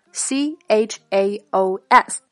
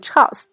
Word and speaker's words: chaos: 第三个，来到我们标题中提到的 C H A O S，千万可别按照拼音的读法把它读成 chaos，哎，这样就闹笑话了。它的正确发音是 chaos，chaos 表示混乱、混沌，形容一派没有秩序的状态。